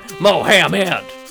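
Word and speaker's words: Mohamed: Mo-ham-ed. It's like Mo and then ham, like a baked ham.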